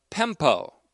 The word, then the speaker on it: pempo: And there's another word that's almost exactly like it in meaning, not in spelling, but in meaning, and that's the word pempo, P-E-M-P, long O.